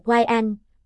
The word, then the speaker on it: Wayan: Các tên này là Wayan, phát âm là Wayan.